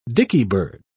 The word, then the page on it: dickybird: British English